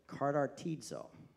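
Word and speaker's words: katartizo: and it's actually pronounced, and I wrote it down because I always say it wrong, because there's another Greek word that's very similar, and it's pronounced this way, kartartizo.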